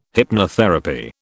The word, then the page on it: hypnotherapy: British English